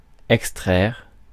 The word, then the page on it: withdraw: [wɪθ.ˈdɹɔ]; /wɪðˈdrɔː/; /wɪθ.ˈdɹɔː/; /wɪð.ˈdɹɔː/